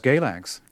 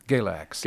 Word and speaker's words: Galax: Early on, you went to a town, I don't know how to say the name of the town, but it looks like Galax. – Yeah, it's Galax. – Galax.